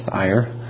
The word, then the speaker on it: Ayr: But in Edinburgh in 1557, in Perth in 1559, in Leith or Leith in 1560, in Ayr, spelled like air, but I believe it's pronounced Ayr, in 1562, in the renewal of the whole nation of Scotland, covenant renewal in 1648 and 1649, the entire nation, again, covenanted.